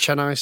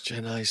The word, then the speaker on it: Chennai: Chennai 6, yes. – Chennai 6, yeah.